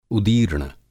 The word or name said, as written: उदीर्ण